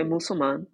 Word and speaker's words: Musulman: Am I saying it correctly? Okay, Musulman. Mm-hmm.